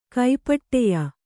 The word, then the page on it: ಕಾಪಟ್ಯ: kāpaṭya